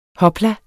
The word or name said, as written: hopla